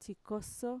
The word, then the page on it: txikoso: Mixtec